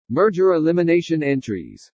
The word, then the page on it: MERGER ELIMINATION ENTRIES: english